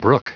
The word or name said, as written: brook